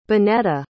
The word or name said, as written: benetta